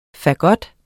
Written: fagot

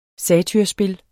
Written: satyrspil

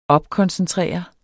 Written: opkoncentrere

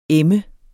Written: em

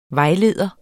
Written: vejleder

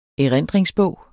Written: erindringsbog

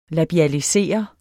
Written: labialisere

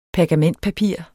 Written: PERGAMENTPAPIR

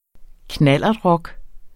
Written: knallertrock

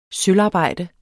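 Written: sølvarbejder

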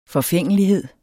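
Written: forfængelighed

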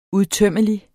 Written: udtømmelig

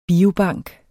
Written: biobank

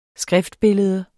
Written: skriftbillede

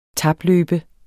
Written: tapløbe

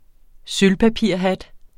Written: sølvpapirshat

